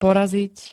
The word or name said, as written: poraziť